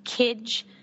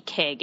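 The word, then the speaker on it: Kij: It's a collection of short stories by Kidge Johnson. And I really hope I'm pronouncing her name correctly. It could also be Kig. It's K-I-J Johnson.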